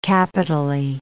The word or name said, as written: capitally